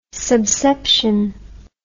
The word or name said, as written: subception